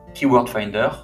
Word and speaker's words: KW Finder: Et ensuite, tu vas pouvoir utiliser une solution payante de recherche de mots-clés comme Keyword Finder. Donc, ça s'écrit KW Finder. Donc, KW, c'est comme, c'est l'acronyme de Keyword.